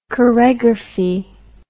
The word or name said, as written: choregraphy